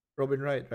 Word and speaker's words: Robin Wright: Robin Wright, right? – Robin Wright, yeah.